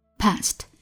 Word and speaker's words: passed: Past, pass, 轻辅音 s 结尾加了 ed, 后面的 ed 读出来是 t, past.